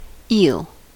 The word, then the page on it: eel: American English